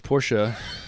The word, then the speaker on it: Porsche: Well, it's Porsche. – Thank you, for saying it correctly.